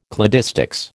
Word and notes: cladistics: American English

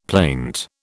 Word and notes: plaint: British English

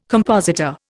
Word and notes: compositor: Indian

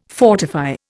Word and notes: fortify: Indian

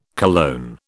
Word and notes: cologne: British English

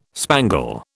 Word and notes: spangle: British English

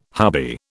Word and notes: hubby: British English

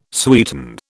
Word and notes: sweeten: British English

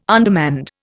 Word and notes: undermanned: Indian

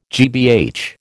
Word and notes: GBH: American English

